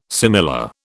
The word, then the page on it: similar: British English